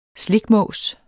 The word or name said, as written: slikmås